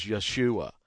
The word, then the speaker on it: Yeshua: So, yes, Jesus' Hebrew pronunciation is Yeshua. That is the way the Hebrews say it.